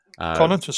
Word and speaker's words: Carnuntum: The Battle of Carnuntum. – Carnuntum, spot on.